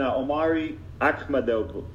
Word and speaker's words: Omari Akhmadov: But let's do start off with the first fight of the main card, and that's Tim Bosch and Omari Akhmadov. – I think you pronounced it right. You're doing a good job.